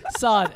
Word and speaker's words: Saad: Saad, S-A-A-D.